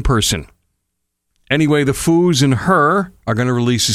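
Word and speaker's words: H.E.R.: It's spelled H period E period R period, but it's her. And it's actually an acronym. It stands for Having Everything Revealed.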